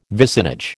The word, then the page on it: vicinage: American English